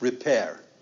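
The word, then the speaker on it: rapere: R-E-P-E-R-E, and it's pronounced repair.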